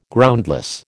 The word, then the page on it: Groundless: American English